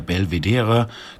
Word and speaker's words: Belvedere: Die beiden leiten das Ungers-Archiv für Architekturwissenschaften, das sich heute genau in diesem Haus in der Belvedere, kölnisch ausgesprochen Belvedere-Straße, befindet.